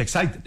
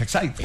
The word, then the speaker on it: Exide: Se escribe Exide, se pronuncia Excite, dentro la... el comercial de las baterías. – Sí, señor. – Así se escribe Exide, se pronuncia Excite.